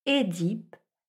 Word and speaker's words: Œdipe: Mais attention, cette première syllabe, qu'elle s'écrive E accent aigu ou E dans l'eau, doit se prononcer avec un E fermé. Il faut donc dire Œdipe, œcuménique ou œsophage.